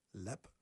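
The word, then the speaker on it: Leap: The 9th, Connolly's of Lepp, pronounced correctly. – Correct.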